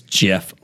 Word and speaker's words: GIF: But the gentleman who apparently created it said it is GIF like the peanut butter. – Okay. – On account of the G being a soft G for what it stands for.